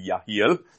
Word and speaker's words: Jehiel: The Spirit of the Lord came upon Jehaziel, son of Zechariah, son of Benaiah, son of Jeiel, or Jehiel, it was very difficult to pronounce, it's actually Yahiel, son of Mattaniah, a Levite of the sons of Asaph, in the midst of the assembly.